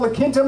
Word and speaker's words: La Quinta: If you've seen that, La Quinta, is it called La Quinta, Mike? – Yeah. – Mario, how do I say that? – You said it correctly.